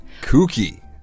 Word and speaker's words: kooky: Yeah, but when you think about it, though, it's not because this word is spelled K-O-O-K-Y, but it's pronounced kooky. – Kooky. – Kooky. It's not pronounced like, for example, cookie. Or cook. They're pronounced very differently.